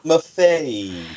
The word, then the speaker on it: Maffei: And it's from Villa Mafia. – Maffei. M-A-F-F-E-I.